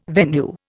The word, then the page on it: venue: Indian